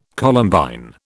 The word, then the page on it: Columbine: British English